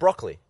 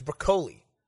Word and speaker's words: Broccoli: That's not how you pronounce his name. It's Broccoli. – It isn't. – I just learned that. It's not. It's Broccoli? – Yeah. – It's spelled like broccoli. – But I've heard it's Broccoli. – It's not. If your last name was Broccoli, you would also say it's Broccoli.